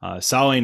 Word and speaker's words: Samhain: Samhain is not spelled the way it sounds. It is spelled Samhain, even though it is said not like that at all.